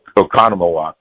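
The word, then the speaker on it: Oconomowoc: Economowoc. Did I say that right? – Yeah, we agree with you.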